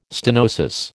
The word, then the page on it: stenosis: American English